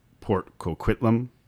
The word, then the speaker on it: Port Coquitlam: I think it's pronounced Port Coquitlam. – Yes.